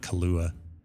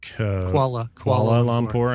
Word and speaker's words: Kuala Lumpur: It's not Kalua. – Kuala Lumpur. – I never say it right. Kuala Lumpur.